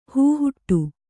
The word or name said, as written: ಹೂಹುಟ್ಟು